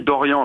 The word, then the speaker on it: Doriand: Mais on a rajouté un D à la fin de mon nom pour justement ne pas que se prononce Dorian. – Ah. Donc ça s'écrit Dorian avec un D à la fin comme euh, Bernard ou ah.